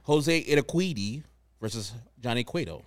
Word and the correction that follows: Urquidy: It's Urquidy. Yeah, you're saying it. You're saying it close enough, I think.